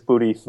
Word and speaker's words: Budi: Yeah, so my name is Budi Voogt. – Okay. – Now that G is difficult. – And it's spelled B-U-D-I, and then the last name is V-O-O-G-T.